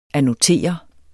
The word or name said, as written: annotere